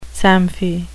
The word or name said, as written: samfie